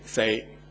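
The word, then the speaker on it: say (四): Because 4, it is pronounced this way, all right? It's a little subtle. You have to listen carefully if you don't understand Cantonese. It is yap yi sam say, say, all right?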